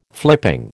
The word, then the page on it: flipping: American English